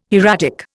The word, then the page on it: hieratic: Indian